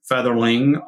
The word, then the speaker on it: Fetherling: Uh, I'm Fetherling on LinkedIn.